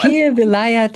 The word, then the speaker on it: Pir Vilayat: They both studied intensively for over 4 decades and now represent, I'm hoping I pronounce this right, Sufi Master Pir Pervil. – Here. – Here, help me with this one. – Pir Vilayat Got it. Thank you so much.